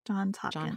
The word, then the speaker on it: Johns Hopkins: Did I say that right? Johns Hopkins. Johns Hopkins. Hopkins. Yeah. – Johns Hopkins.